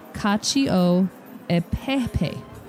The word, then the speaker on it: Cacio e Pepe: I know, but it has it later, so I'm going to read it the way that I normally say it, which is Casio e Pepe. And she says, I know this has been a topic of discussion before, so for the record, it's pronounced Cacio e Pepe. – Yeah, we're never going to remember that. No. – Cacio e Pepe is how it comes out.